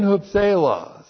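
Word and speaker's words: hupselos (ὑψηλοῖς): Preposition N, and then that's the letter H, the mouth breathing over the word. H-U-P-S, long E-L-O-S, N, hupselos. So point three, N, hupselos, means on high, but it means way, way more than just on high.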